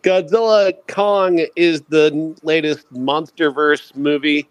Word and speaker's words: Godzilla x Kong: The X in Godzilla X Kong is silent, just so you know. It's just okay. Godzilla Kong. – Godzilla Kong.